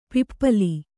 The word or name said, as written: ಪಿಪ್ಪಾಲ